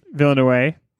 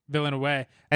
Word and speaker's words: Villeneuve: Villeneuve. – Villeneuve.